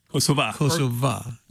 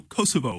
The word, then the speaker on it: Kosova: Kosova. Known as also Kosovo. – Yes, yes. I just found out I've been pronouncing it like a Yank, you know, and it is actually in the nation. It's Kosova.